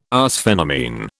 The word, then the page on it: arsphenamine: British English